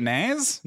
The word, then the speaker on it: Nas: Is that how you say it? Nas. Mm-hmm. Gotcha.